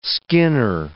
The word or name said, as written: skinner